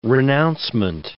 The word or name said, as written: renouncement